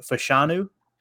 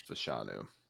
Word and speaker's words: Fashanu: Is it Fashanu? – I think that's how you pronounce it. I believe so. Right. I believe it's Fashanu.